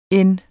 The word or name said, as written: end